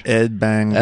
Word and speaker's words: Ed Banger: You have to say it like French. – Ed Banger. – Ed Banger. Ed Banger. Swallow every syllable.